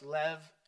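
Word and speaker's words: lev (לב): And the second Hebrew word is L-E-B. L-E-B is pronounced lev. A B in Hebrew is a V. Lev. L-E-V, and it means the inner man, the source of life from the inner person.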